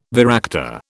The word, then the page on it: varactor: British English